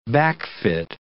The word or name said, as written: backfit